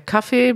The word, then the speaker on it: Kaffee: Also der Kaffee, Betonung erste Silbe, kurzes A. Das Kaffee, Betonung zweite Silbe, langes A.